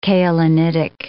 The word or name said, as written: kaolinitic